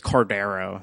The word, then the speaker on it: Cordeiro: Cordeiro? Why do you got to say it like that? It's Portuguese. – Okay. It's Cordeiro.